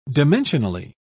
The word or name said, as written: dimensionally